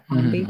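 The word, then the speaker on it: Mombi: And also she is the original Princess Mumby. Mumby? Am I saying that right? – I think so. Yeah. – Mumby. – Mm-hmm, Mumby.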